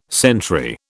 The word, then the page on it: sentry: British English